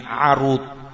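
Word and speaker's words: arud (عَروض): Karena di sana ada lafaz lain arud dengan fathahnya ain, arud.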